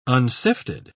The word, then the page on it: unsifted: British English